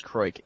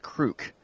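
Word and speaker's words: Kreuk: Also, the actress on Smallville's last name is not pronounced Croik, instead it is pronounced Krook.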